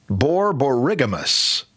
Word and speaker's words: borborygmus: Thanks to one of our astute callers who helped me out with the pronunciation of Hank's word. It is borborygmus, again, which is something wrong with your gut.